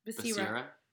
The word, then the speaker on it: Becerra: And then they also had California Attorney General Javier Becerra, I think is Becerra? Becerra? – Becerra. – Becerra. That's right. Sorry.